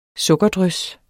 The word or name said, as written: sukkerdrys